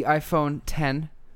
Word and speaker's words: iPhone X: So the iPhone X, that is a Roman numeral X, not pronounced X though, just like Mac OS X was Mac OS X and not Mac OS X.